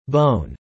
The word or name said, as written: bone